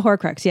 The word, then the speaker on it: Horcrux: It's This Will Make an Excellent Horcrux. – Oh, cool. Yeah. – Did I say that right? – A Horcrux.